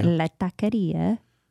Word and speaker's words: La Taqueria: You guys, we love La Taqueria in, did I say that right, by the way? – La Taqueria in San Francisco, but they're closed for their winter break.